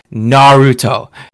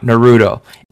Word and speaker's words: Naruto: It's Naruto, not Naruto. It's Madara, not Madara, and it's Uchiha, not Uchiha. So, good job.